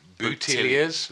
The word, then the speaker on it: Boutilliers: B-O-U-T-I-L-L-I-E-R-S. Boutilliers.